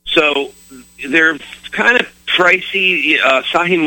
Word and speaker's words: Sahih: In English, if you want to write down these two Hadith collections, one is Sahih Muslim. Sahih is spelled S-A-H-I-H.